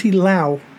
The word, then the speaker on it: Loux: And today we're going to be talking to my friend, my very close friend, Tracie Loux. Sorry, I'm really bad at pronouncing names, but that's how it is. It's Loud with an X, right? – You got it.